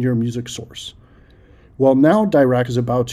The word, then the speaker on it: Dirac: And it's D-I-R-A-C, all capitalized and pronounced DIRAC for some reason.